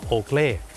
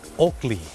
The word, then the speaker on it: Oakley: Oakley แต่เจ้าของภาษาไม่ได้ออกเสียงว่า Oakley ครับเขาออกเสียงว่า Oakley